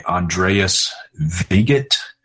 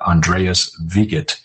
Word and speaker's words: Andreas Wiget: In fact, I would commend to people a YouTube channel named Digging Deeper by Andreas Wiget. That's how it's pronounced, but we spell it W-I-G-E-T, but Andreas Wiget.